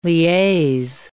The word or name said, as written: liaise